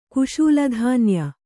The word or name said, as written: ಕುಶೂಲಧಾನ್ಯ